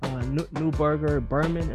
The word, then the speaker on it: Neuberger Berman: I know you did work at JP Morgan, Neuberger Berman.